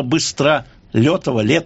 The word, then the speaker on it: Быстролетов: Да, я поясню этот вопрос, я привык произносить Быстролетов, хотя, наверное, с точки зрения русского языка правильно все-таки артикулировать букву «Е».